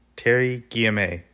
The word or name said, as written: Terri Guillemets